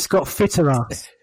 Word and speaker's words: Scott Fitterer: Scott Fitterer. Say it properly, please.